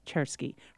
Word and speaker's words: Czerski: And if you want to look her up, because she's fascinating, her last name is spelled C-Z-E-R-S-K-I, Chersky.